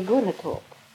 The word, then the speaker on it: Gunnetorp: Ja, det är Gunnetorp. – Men när du talar det gamla så där, så låter det. Gunnetorp. – Ja, jag har inte hört något annat uttal på Gunnetorp. Än Gunnetorp.